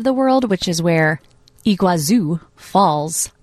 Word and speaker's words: Iguazu: Iguazu Falls. – Iguazu.